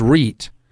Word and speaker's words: REIT: The way you pronounce that is REIT.